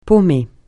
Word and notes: paumer: francais

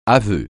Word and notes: aveu: francais

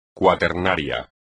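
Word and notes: cuaternario: español

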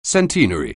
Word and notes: centenary: anglais

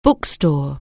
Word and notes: bookstore: anglais

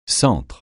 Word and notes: Centre: francais

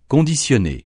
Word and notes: conditionner: francais

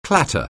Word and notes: clatter: anglais

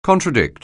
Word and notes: contradict: anglais

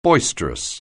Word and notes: boisterous: anglais

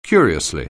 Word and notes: curiously: anglais

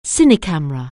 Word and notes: cinecamera: anglais

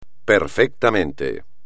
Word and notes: perfectamente: espagnol

